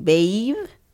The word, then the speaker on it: Baev: It's A-E-V. – Okay, so Andre I got right, but then B-A-E-V is the last name. Baev? Baev? – Yeah. – B-A-V-E? – B-A-E-V. – Oh, Baev. – Baev.